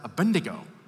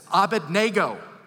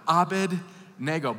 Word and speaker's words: Abednego: By the way, when I was a kid, I was taught to pronounce it Abednego. That's not how it's spelled. It's Abednego.